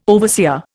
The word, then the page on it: overseer: Indian